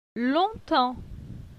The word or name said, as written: Longtemps